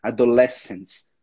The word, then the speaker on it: adolescence: E a mesma coisa para adolescência, né? Que é adolescence.